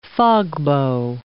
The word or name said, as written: fogbow